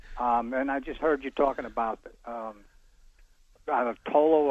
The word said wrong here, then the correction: Toyo: or whatever. How do you spell that? – Toyo. T-O-Y-O.